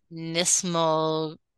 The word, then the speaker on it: knismolagnia: There is something called nismolagnia. – You just made that up. – No, I didn't. The K is silent. – There's a K in there. – There's a K in there, yeah. K-N-I-S-M-O-L-A-G-N-I-A.